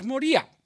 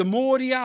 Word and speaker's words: Moria: En als je dat stuk eens wat gaat analyseren, gaat puzzelen, dus wat uit elkaar gaat trekken, dan lezen we daarover die berg Moria. Of de Moria, of het is net waar je de klemtoon wil leggen in het verhaal.